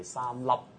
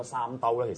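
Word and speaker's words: 三笠: 嗱咁其實仲有一啲嘢要澄清同埋要補充翻，即係上個禮拜咧，咁我哋講咗《進擊之巨人》啦，咁我有少少要更正翻，即係上次我哋講咗一個女主角 Mikasa 嗰個中文嗰個嘅發音咧，我係講錯咗嘅，應該正讀咧就係三粒。跟住咧就，但係如果我講咗三兜咧，其實嗱，呢個唔係話我要點樣解釋，就真係一個係搞爛 gap 搞出喎。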